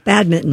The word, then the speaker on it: badminton: You don't pronounce that N? – You do not. – No, badminton.